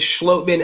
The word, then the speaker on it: Schlottmann: And we have confirmation how to pronounce his last name from the man himself. After, like I told him, I've been mispronouncing it for months now. It is Schlottmann, and I'm so happy and excited to talk to him.